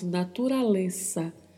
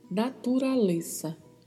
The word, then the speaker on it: naturaleza: Muita atenção aí para a última sílaba. O Z tem som de S, então falamos naturaleza, naturaleza.